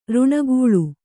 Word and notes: ಋಣಗೂಳು: kannada

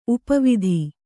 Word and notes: ಉಪವಿಧಿ: upa vidhi

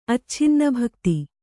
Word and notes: ಅಚ್ಛಿನ್ನಭಕ್ತ: acchinnabhakta; Kannada

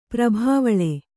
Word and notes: ಪ್ರಭಾವಳೆ: prabhāvaḷe

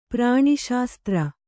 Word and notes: ಪ್ರಾಣಿಶಾಸ್ತ್ರ: kannada

